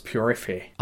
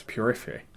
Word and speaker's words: purifié: Yeah, although it's French, so it's purifié. – Okay, but it's spelled like purifier, right? – Yeah, but it's pronounced purifié.